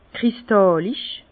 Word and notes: kristàllisch: Bas-Rhin; Herrlisheim

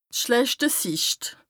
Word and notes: d' schlechte Sicht: Bas-Rhin; Strasbourg

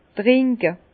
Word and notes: trinke: Bas-Rhin; Herrlisheim

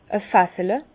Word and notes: e Fassele: Bas-Rhin; Herrlisheim

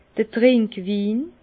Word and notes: d'r Trinkwin: Bas-Rhin; Herrlisheim

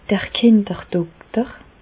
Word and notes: d'r Kinderdokter: Bas Rhin; Reichshoffen

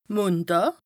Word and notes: Mondàà: Bas Rhin; Strasbourg

